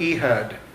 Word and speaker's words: Ehud: Uh, begins in verse 12, and we're going to be introduced to a judge by the name of Ehud. Uh, I don't know how you pronounce that, but Let's just go. It's a long E.